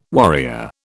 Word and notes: warrior: British English

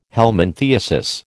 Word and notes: helminthiasis: American English

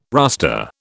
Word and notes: rustre: British English

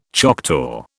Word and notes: Choctaw: British English

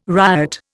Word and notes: ryot: Indian

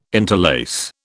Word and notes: interlace: British English

